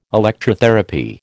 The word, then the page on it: Electrotherapy: American English